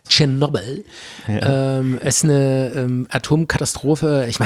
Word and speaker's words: Tschernobyl: Tschernobyl.